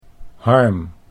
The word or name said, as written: Harm